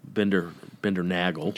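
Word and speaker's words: Bindernagel: So both – it's spelled Nagel, but I heard him pronounce it Nagel, Bendernagel.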